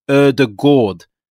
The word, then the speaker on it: Ødegaard: It's actually pronounced Ødegaard. And I got that on good authority yesterday from Norwegian journalist Jonas Jæver.